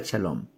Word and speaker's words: shalom: se pronuncia shalom. Y es la paz que menciona la Biblia, es mucho más que simplemente un estado sin problemas. Significa totalidad, bienestar, seguridad y alcanza cada área de nuestra vida. Shalom significa paz a pesar de paz en medio de la tormenta, paz sin importar las pruebas.